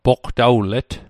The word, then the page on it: poqtawlet: Mi'gmaq